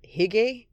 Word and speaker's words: hygge: It's spelled H-Y-G-G-E, I think. Okay, I just paused to look it up because I wasn't sure. Hygge, not hygge, hygge, H-Y-G-G-E. It's a Danish word used when acknowledging a feeling or moment,